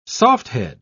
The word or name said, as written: softhead